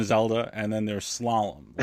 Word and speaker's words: slalom: Like, I get that slalom is a ski term, but even now I don't know what it means. And as a kid, I couldn't even pronounce it. It'd be like, salam. – Slalom.